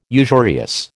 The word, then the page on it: usurious: American English